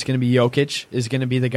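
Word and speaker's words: Jokić: And I did listen to the Denver broadcast, and they did say, I think I actually messed this up just now, but it's Jokic, like, yo, what's up, and Laverne instead of Lavernier, like I've been mispronouncing all season long.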